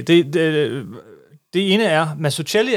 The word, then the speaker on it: Mazzucchelli: Hvis du skal være udtalt på italiensk, er det Mazzucchelli.